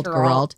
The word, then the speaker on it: Geralt: Geralt.